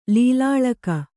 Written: ಲೀಲಾಳಕ

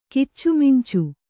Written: ಕಿಚ್ಚುಮಿಂಚು

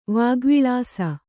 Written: ವಾಗ್ವಿಲಾಸ

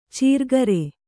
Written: ಚೀರ್ಗರೆ

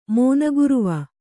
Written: ಮಾನಗರ್ವ

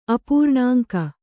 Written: ಅಪೂರ್ಣಾಂಕ

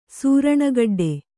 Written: ಸೂರಣಗಡ್ಡೆ

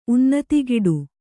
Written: ಉನ್ನತಿಗಿಡು

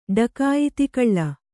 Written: ಡಕಾಯಿತಿಕಳ್ಳ

